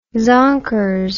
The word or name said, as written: zonkers